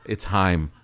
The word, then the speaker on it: Haim: She's like, "It's Haim."